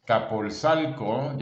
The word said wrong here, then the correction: Azcapotzalco: ya no sé ni cómo se dice, la verdad. Azcapotzalco. Okay. Azcapotzalco.